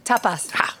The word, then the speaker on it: tapas: I love the topos, Peppa. Is that how you say it? – No, you say tapas. Tapas. No.